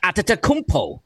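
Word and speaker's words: Antetokounmpo: Atetokounmpo.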